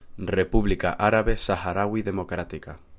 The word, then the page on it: República Árabe Saharaui Democrática: Spanish